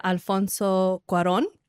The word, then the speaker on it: Alfonso Cuarón: Is it Alfonso Cuarón? – That sounds exactly right to me.